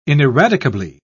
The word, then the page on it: ineradicably: British English